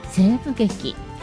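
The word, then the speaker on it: 声舞劇: 西部劇、これはですね、アメリカ西部劇ではなく声に舞う劇と書いて西部劇と読むんですね。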